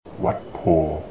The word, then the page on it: Wat Pho: short